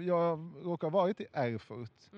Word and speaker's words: Erfurt: I staden Erfurt. – Mm, bra uttal. – Tack. Eller det var alltså inte bra uttal? – nej, Erfurt.